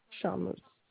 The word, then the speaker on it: Charmoz: Charmeuse. – Cela s'écrit comment? – C-H-A-R-M-O-Z. – M-O-Z. C-H-A-R-M de maman? – Non, M.